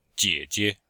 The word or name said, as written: jie3jie5